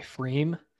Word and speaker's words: Pfriem: Introduced me to an IPA made by Freem, which is a really interesting name. It's actually spelled P-F-R-I-E-M, Freem IPA. It's good stuff. – One of those silent P's. – The silent P, it'll get you.